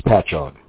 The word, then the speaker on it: Patchogue: Pat Newgie. Am I saying that right? Pat Newgie. – If you take your finger and you hold it over the UE and forget the UE, it just says Patchogue.